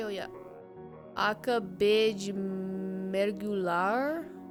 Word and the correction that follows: mergulhar: Yeah, but how is my pronunciation? – Mergulhar. – Mergulhar. – Yeah.